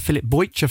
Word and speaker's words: Bojić: And I'm also joined this evening by Filip Bojić.